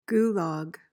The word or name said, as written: gulag